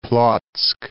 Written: Plock

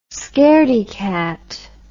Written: scairdy cat

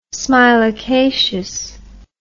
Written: smilacaceous

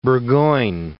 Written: Burgoyne

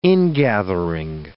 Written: ingathering

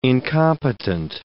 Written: incompetent

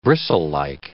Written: bristlelike

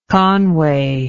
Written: conway

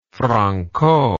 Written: Franko

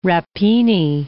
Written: rappini

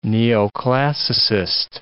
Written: neoclassicist

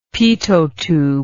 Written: pitot tube

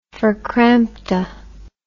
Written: verkrampte

